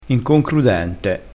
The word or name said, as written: inconcrudente